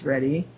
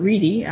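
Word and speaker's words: Ready: So now we have Barney Frank wedding Jim Reddy, I guess his name is pronounced Reddy and not Reedy. I'll pronounce it that way.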